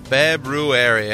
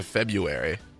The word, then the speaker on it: February: February, eh? A lot of people pronounce it February. But there's another R in there, you know? February. There we go.